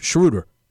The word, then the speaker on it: Schroeder: By the way, it's Schroeder. Everyone's going to pronounce his name. I think I've heard 9 different pronunciations in the past week and a half. Schroeder.